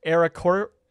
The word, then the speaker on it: Aarakocra: Aarakocra.